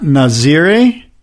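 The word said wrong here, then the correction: Nazaré: Not How do you pronounce it? – Nazare. – Okay, Piahi, not Nazare, not Waimea, not Todos, not Mavericks.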